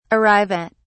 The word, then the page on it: arrive at: British English